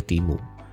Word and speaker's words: Temu: Jadi kalau teman-teman belum tahu, di US itu ada aplikasi yang viral banget namanya Timu, T-E-M-U ya bacanya Timu.